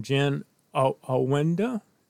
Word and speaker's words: Jen Awinda: And I'd like to welcome Jen Awinda.